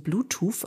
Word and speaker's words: Bluetooth: Das sind Jossie Mitsu und Bluetooth aus Birmingham. Das war jetzt übrigens nicht ich, wie ich sehr schlecht ein TH ausspreche, sondern er heißt wirklich Bluetooth, also kein Versprecher an der Stelle.